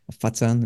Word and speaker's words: Foshan: Fatsan is how you say it over there.